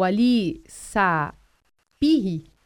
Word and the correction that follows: Ali Sipahi: acho que é assim que se pronuncia corretamente, pelo ministro do Supremo... – Ali Sipahi. – Ali Sipahi. – Não é Ali, é Ali. Ali Sipahi.